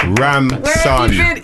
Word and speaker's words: Ramson: Ramson. – Yes, Ramson. – People always say it wrong as well, innit? – What's that? – They always say Ransom and it's Ramson. – It's Ramson. Son, innit? – Ramson. – R-A-M-S-O-N.